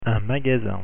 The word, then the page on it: magasin: French